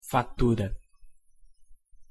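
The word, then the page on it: fatura: Portuguese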